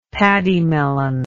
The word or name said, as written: pademelon